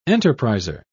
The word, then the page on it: enterpriser: British English